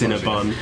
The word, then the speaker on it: Cinnabon: Now, something else we noticed in the Mall of America is that we went to Cinnabon. That's exciting. We never heard of it. Am I pronouncing it right? – Uh-huh. Yes. – Cinnabon.